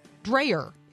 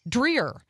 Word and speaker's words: Dreher: In order to dissuade you from one lie you have begun to believe, his name is not pronounced Dreher. It's pronounced Dreher.